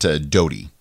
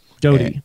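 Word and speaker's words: Dodee: Well, the voice actor doing the 34 and a half hours of Under the Dome, I believe pronounces it Doty. – Doty.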